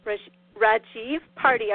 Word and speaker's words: Rajiv Parti: But this is our first show of the fall season, and I'm so happy to have Dr. Rajiv Parti.